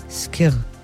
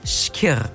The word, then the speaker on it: Skyr: Dieses Rezept geht gerade so viral, dass Kirr sehr gefragt ist in den Supermärkten. Und bevor das hier ein Durcheinander gibt, ich habe unsere Aussprachedatenbank erstmal gefragt, wie wird das überhaupt richtig ausgesprochen? – Skirr. – Skirr.